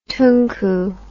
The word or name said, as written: tunku